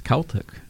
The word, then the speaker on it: Celtic: So you can finally answer the question, Celtic or Celtic? It's Celtic.